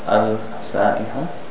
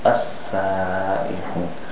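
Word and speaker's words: السَّائِحُونَ: The lam, you notice it's not al-sa-ihun, as-sa-ihun. This is called a sun letter.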